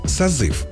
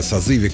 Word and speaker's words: созыв: Одно из таких слов – созыв. Нормативное ударение в этом слове падает на второй слог. Тем не менее, даже депутаты разных уровней, говоря о созыве какого-либо из представительных органов власти, произносят «созыв». В этом ряду не только народные избранники,